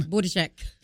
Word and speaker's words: Buttigieg: So I just hope with all this big money, Mr. Pete Buttigieg, I know I butchered the name. – Buttigieg. – And how do you say it? – Buttigieg.